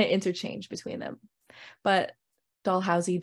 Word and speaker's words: Dalhousie: See, one time I said Dollhousey to a Brantford local and they laughed at me because apparently it's pronounced Dalhousie.